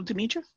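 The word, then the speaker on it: Demitra: Oh, Pavel Dimitra? – Yes.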